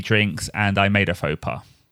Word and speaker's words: faux pas: Then I made a faux pas again!